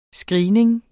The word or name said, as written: screening